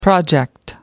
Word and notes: project: American English

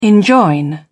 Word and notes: enjoin: British English

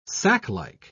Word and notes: saclike: British English

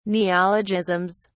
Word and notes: neologisms: British English

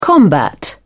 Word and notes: combat: British English

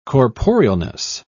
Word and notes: corporealness: British English

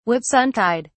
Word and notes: whitsuntide: British English

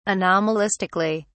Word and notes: anomalistically: British English

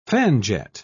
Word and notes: fanjet: British English

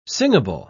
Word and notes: singable: British English

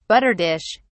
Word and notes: butter dish: British English

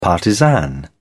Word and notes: partisan: British English

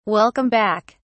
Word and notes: welcome back: British English

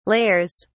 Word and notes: layers: British English

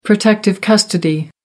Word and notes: protective custody: American English

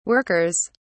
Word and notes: workers: British English

